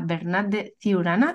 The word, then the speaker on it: Bernat de Ciurana: Así que voy a dar la bienvenida a Bernat de Ciurana. Lo he dicho bien, ¿verdad? Porque... – Sí, lo has dicho bien, lo has dicho bien.